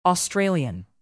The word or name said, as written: australian